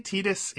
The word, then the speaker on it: Tidus: and it was Sora talking to Tidus, who is the fighting Tidus, who's the protagonist of. – Is it Tidus? – They say Tidus in Kingdom Hearts, so that's what I'm going off of.